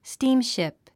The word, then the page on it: steamship: stíːmʃìp; スティームシプ